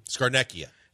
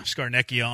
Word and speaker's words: Scarnecchia: Dominic Scarvoni in the winter. Yeah. I don't know how to say his name. – Scarnecchia. – That's it. Yeah, Scarnecchia on the neck.